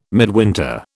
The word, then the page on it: midwinter: British English